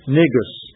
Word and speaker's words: Negus: How do you say that? N-E-G-U-S. Negus.